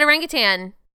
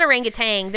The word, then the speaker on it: orangutan: No, I said orangutan. It's not orangutan. There's no G at the end.